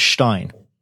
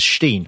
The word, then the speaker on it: Frankenstein: Frankenstein. – Right. Except that the way it's spelt in Germany, you would pronounce that as Stein, but they sing it as Steen.